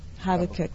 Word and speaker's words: Habakkuk: It's in Habakkuk. How do you say it? Habakkuk. Okay, Habakkuk. I want to say it the African way, isn't it funny? Habakkuk.